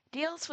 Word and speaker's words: Diels: One individual in particular that I thought was worth mentioning is, I also apologize, I may pronounce this wrong, is Rudolf Diels or Diels. – No, you had it right.